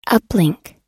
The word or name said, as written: uplink